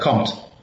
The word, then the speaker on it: COMT: When we were chatting yesterday in preparation of today, you spoke also about a COMT gene. Am I pronouncing it right? – Yeah, COMT. Yes.